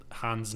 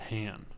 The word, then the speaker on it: Han: Because in Empire, Billy Dee Williams, Lando, always said Han instead of Han.